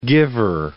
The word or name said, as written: giver